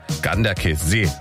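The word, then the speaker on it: Ganderkesee: Herzlichen Dank nochmal für die Aussprachetipps, die schnell reinkamen von unserem Radioarzt Jürgen in Gorch, der mir gleich geschrieben hat, Ganderkessee wird das ausgesprochen.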